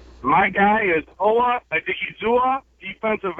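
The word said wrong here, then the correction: Odighizuwa: Okay, according to this, it is pronounced Owa Mabe Odigizua.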